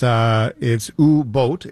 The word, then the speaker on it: Boot: it's ooh boat, BOOT is pronounced BOHT.